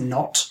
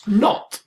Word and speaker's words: Naught: N-A-U-G-H-T, like the British say zero. – Yes. – Knot. – Knot.